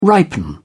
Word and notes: ripen: British English

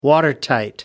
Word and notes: watertight: American English

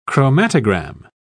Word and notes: chromatogram: British English

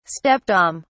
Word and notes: stepdame: British English

